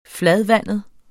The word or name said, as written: fladvandet